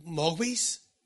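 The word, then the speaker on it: Mogwai: The little Mogwais? That's the pronunciation.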